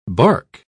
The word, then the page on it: barque: British English